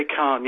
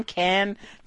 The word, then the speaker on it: Cannes: You took yourself off to, now is it Cannes? – I say Cannes, yeah. – Cannes. We hear so many pronunciations.